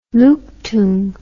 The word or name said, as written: luktung